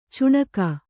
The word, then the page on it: ಚುಣಕ: cuṇaka